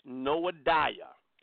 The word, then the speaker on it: Noadiah: Her name is spelled N-O-A-H, like Noah. Noah, and then I-D, let me spell it again, N-O-A-H, like Noah, D-I-A-H, Noadiah. Noadiah, the false prophetess.